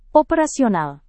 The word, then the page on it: operasional: Indonesian